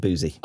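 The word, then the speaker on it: Boosey: Well, it's spelled the same as the music publishing house Boozy and Hawks. – Okay. – And that's pronounced Boozy.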